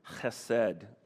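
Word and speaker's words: chesed (חֶסֶד): It's the Hebrew word chesed, okay? You got to get a little something in the back of your throat to really say it right, okay?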